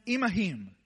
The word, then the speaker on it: imahim: But in the Hebrew, in the original language of this text, in verse 12, when he says, go with them, it's a Hebrew word that says, that is pronounced imahim.